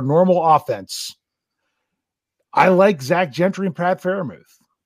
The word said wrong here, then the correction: Freiermuth: I, I, I, butchered his name, Freiermuth. – There, there, I got it right.